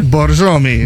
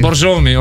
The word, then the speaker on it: Borjomi: It's called Borjomi. – Borjomi. – Borjomi, okay, I pronounce it right.